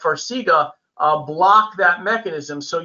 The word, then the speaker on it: Farxiga: Um, Will Farxiga, I'm probably pronouncing that incorrect, Farxiga, F-A-R-X-I-G-A, will that help with edema? – Yeah, so you pronounced it correctly. It's Farxiga.